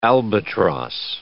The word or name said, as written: albatross